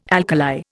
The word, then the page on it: alkali: Indian